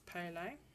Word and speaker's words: Pele: I hope I'm pronouncing that correctly. Let me just check. Pele, sorry. It's pronounced Pele.